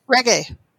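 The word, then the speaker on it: Regé-Jean: How do you say his first name? – Reggae. – It's Reggae.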